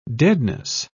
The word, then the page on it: deadness: British English